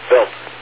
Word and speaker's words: Wild: Comet Wild 2 is the way you would spell it. W-I-L-D, but it's really pronounced Built, like B-I-L-T. Built 2, but spelled Wild, W-I-L-D.